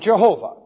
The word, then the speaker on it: Jehovah: So, we pronounce it Jehovah. There are different ways of pronouncing it, but this is basically the verb to be doubled, which means perfect existence.